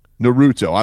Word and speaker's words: Naruto: I don't know how to say it. – Naruto.